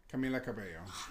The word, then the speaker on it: Cabello: Camila Cabello. – Oh my God, I can never say it. – You say the L's. – Camila Cabello. – Yeah, stop saying the L's.